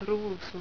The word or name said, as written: ruusu